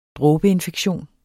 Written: dråbeinfektion

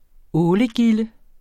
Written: ålegilde

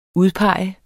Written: udpege

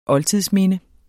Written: oldtidsminde

